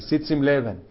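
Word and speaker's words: Sitz im Leben: El Sitzim Leben, o Sitzim Leben, ¿cómo se pronuncia? Leben, como tu apellido, ¿no? Casi, casi, Sitzim Leben.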